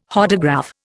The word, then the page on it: Hodograph: Indian